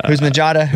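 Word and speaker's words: Houshmandzadeh: And he's got a new show with Plaxico Burress and TJ Houzmanjada. Did I say that right? – Yeah. – Houzmanjada.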